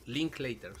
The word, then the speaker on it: Linklater: De Richard Linklater, sí.